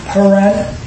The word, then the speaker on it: Pur: So first is little thing, but today is Purim. It's actually pronounced Pur, P-U-R, and that's in the book of Esther.